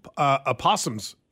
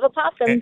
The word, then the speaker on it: opossum: This is the American pronunciation. – There's only opossum. – One. I'm sorry? – Opossum. – Oh, I hear an O there.